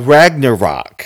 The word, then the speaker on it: Ragnarok: It's it's Thor Rangamuffin, something like that. – Uh, Ragnarok.